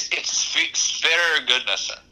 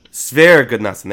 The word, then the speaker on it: Sverre Gudnason: It's Sverre Gudnason. – Sverre Gudnason. There we go.